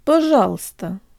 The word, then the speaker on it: пожалуйста: П жал ста пожалуйста пожалуйста пожалуйста,